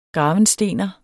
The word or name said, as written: gravenstener